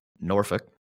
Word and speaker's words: Norfolk: Okay, so it's Norfolk. Yeah. And the way that they stratify people who either grew up there, have lived there, or have just seen it on the map, is based on how you pronounce the city name. So if you say Norfolk, we know that you're a casual. You've never lived there. You're not from there. You've just seen it on a map.